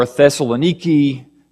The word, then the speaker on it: Thessaloniki: But the same could be said to Ephesus or Galatia or Colossae or Thessaloniki. That's how they pronounce it now.